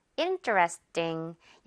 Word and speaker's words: interesting: 이렇게 하지 말고요. interesting. 약간 띵에 훨씬 가깝게 발음을 해보세요.